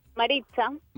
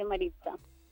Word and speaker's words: Maritza: Maritza. – Maritza, se escribe con T y Z, pero se dice Maritza.